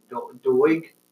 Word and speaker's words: Doig: Yeah, Alexa Dog. Doig. – Doig. – Yeah, Doig. Come on, guys, don't butcher her name.